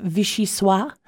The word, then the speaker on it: vichyssoise: Also, National Vichyosi Day. I'm not French, so I don't know how to pronounce that, but it's a thick soup made of boiled and pureed leeks. – Are you talking about vichyssoise? – Yes, of course. Vichyssoise. Maybe I should have had you say that one. – Okay. Well, I think that's how it's pronounced.